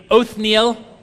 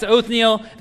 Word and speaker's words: Othniel: Everybody say together the name Othniel. I grew up for a long time pronouncing that wrong. I'm not going to tell you what the wrong pronunciation is because then it'll get into your head. But Like, it was only a couple weeks ago I thought, wait a second, I was adding a letter to that name. So that's Othniel.